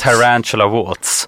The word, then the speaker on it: The Tarantula Waltz: The Tarantula Waltz. – Ja. – Tarantula som spindel och Waltz som i vals då.